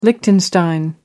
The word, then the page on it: liechtenstein: American English